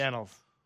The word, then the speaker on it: annals: Is it the annals or the annuals? – The annals.